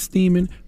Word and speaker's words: Steeman: Please let Remy Ma give Thomas Steeman, rhymes with semen, the biggest hee-haw.